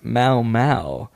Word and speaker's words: Momo: She wanted me to shout out her YouTube channel, but she says it's pronounced Mau Mau, and a space between Jess and the M-O-M-O show. Jess space M-O-M-O show, pronounced Mau Mau.